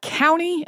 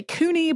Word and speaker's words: Couney: I've heard people pronounce his name a couple of different ways. I've heard some people say County, and I've heard some people say Couney, but Couney seems to be the more common.